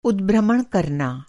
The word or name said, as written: उदभ्रमण